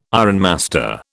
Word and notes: ironmaster: British English

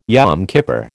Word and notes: Yom Kippur: American English

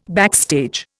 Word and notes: backstage: Indian

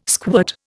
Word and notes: squirt: Indian

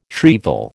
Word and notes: shrieval: American English

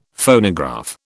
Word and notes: Phonograph: British English

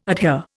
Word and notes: adhere: Indian

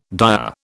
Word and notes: dire: British English

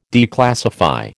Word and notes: declassify: American English

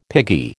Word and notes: piggy: American English

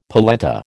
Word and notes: polenta: American English

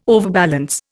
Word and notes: overbalance: Indian

